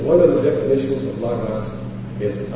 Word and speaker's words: Logos: Many people like to say Logos, but it's not Logos, because it's Lambda, Omicron, Gamma, Omicron, Sigma. Logos.